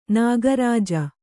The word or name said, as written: ನಾಗರಜ್ಜು